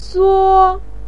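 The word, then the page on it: suo: tone 1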